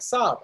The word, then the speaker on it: Saab: He was like, Saab. I was like, that's it, Rob. We'll just call it Rob, R-A-A-B.